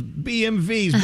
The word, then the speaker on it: BMW: BMV. – BMV. Wow. – There you go. – That's so crazy. – So now from now on, you'll go, hey, look at that. Look at that a-hole's BMV's broken down on the side of the road.